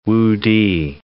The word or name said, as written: Wu-ti